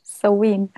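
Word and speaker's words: Samhain: Sam, ich sag mir bitte noch einmal, wie es ausgesprochen wird. So Wien. So Wien.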